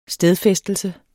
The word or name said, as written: stedfæstelse